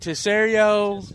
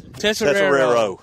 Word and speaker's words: Tesoriero: What we decided to say, Coach, is it Tesserio? – Tesserero. – Coach Tesserero.